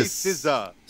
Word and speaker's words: SZA: It's like saying scissors. Just say SZA. – SZA? Is that SZA? – There you go.